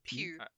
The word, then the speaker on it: Pugh: Pugh. It's English. – I think she could be really good. – Yeah, so it's the gal from Midsommar, yeah? – Yes. – I could get behind Florence Pugh in that role for sure.